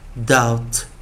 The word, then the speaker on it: doubt: ouch c k k k couch couch couch cr cr cr cr crouch crouch crouch v v v v vouch vouch vouch 再来哦，d 啊，最后一遍啊， O U B T 的时候， B silent 不发音。 Out, out, out, out, doubt, doubt, doubt。